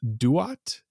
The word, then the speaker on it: Duat: Duat. – Am I saying that correctly? I believe so, yes.